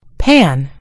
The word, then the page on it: pan: British English